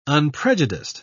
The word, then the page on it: unprejudiced: British English